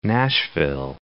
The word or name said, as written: Nashville